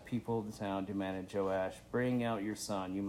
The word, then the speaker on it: Joash: How do you say that? Joash? Yeah.